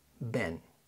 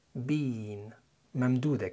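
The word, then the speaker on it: been: الغالبية هتلاقيهم بينطقوها بين ممدودة كده.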